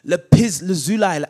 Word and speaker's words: lapis lazuli: They saw the God of Israel, that under his feet was something like, it was blue, like lapis lazuli, as PT says that is correctly pronounced.